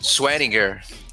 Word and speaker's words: Swearinger: É, o meu terceiro vai pro, pro safety, o DJ Swanger, Swanger, sei lá como é que fala Swanger, essa porra. É. – Swearinger. É,